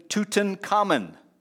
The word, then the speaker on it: Tutankhamun: King Tut, King, let me get the name right, King Tutankhamun.